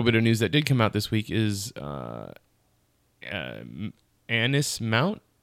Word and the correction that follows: Anson Mount: Anson Mount and Rebecca Romaine,